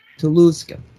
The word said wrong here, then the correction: Tunguska: Tunguska. – How do you spell it? – It's Tunguska. Tunguska.